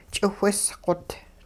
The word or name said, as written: ch'iwhisqot